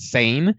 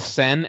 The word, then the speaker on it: Sen: I would always pronounce it Sane, and I just have to keep thinking in my head, it's Sen as in Senate, and it, even when I was preparing for this episode, I'm like, remember, Senate, don't say it wrong.